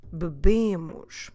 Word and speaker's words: bebemos: Notice now that the first syllable, it's not a stressed syllable because we always stress the second to last syllable, so that BE, or in Portuguese BE, that E disappears. We just go BE, bebemos, because now we're stressing the B bit.